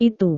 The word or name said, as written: itu